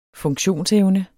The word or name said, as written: funktionsevne